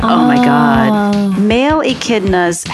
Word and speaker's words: echidnas: Echidnas? – Echidnas, oh.